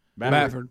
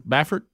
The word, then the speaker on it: Baffert: Baffert. – Baffert, mm-hmm.